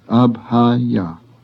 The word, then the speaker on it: abhaya: The first word has three short A's, abhaya, meaning non-fearing.